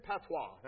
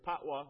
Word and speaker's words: patois: Jamaican patois, that's how you pronounce that word. It is essentially known locally as either patwa or patwa, and called Jamaican Creole by linguists.